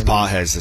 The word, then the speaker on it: Pages: I think it's Pages. That's how you say it.